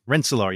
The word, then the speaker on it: Kilian van Rensselaer: He's a trained lawyer hired to manage the huge estate belonging to a patroon named, help me with this pronunciation, Kilian van Rensselaer. Rensselaer, yes. All the way upriver.